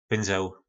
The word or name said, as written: pynzel